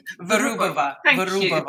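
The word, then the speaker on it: Vyrubova: I hope you're going to correct my Russian pronunciation on the way through. – Virubova. – Thank you.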